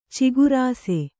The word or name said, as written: ಚಕಾರಿಸು